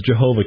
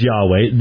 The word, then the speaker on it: Yahweh: They recognize that most scholars identify the more likely pronunciation, well, certainly more likely because Jehovah can't possibly be the actual pronunciation, as Yahweh.